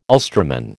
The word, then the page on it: Ulsterman: American English